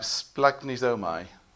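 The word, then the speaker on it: splanchnizomai: but it's pronounced something like splagnizomai.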